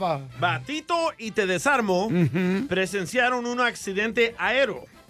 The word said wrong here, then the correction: aéreo: No, no se dice aero. ¿No? aéreo. – Aéreo.